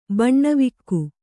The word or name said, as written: ಬಾನ್ವಕ್ಕಿ